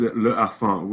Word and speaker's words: Le Harfang: Can you give us the pronunciation again? – Well, yes, it is Le Harfang, which means snow owl.